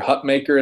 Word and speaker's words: Hutmacher: You hope that Nash Hutmacher, or Hutmaker, as I believe he corrected.